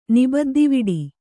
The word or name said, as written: ನಿಬದ್ದಿವಿಡಿ